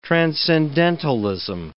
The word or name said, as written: transcendentalism